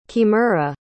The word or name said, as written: kimura